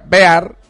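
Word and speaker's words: Behar: El libro de Eddie Bear, Eddie Bear se pronuncia Bear, se escribe B-E-H-A-R, H intercalada.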